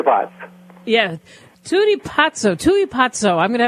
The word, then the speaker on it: pazzo: "Tu e pazzo" means you're crazy, And Pazzo is spelled P-A-Z-Z-O, like pizza, Pazzo.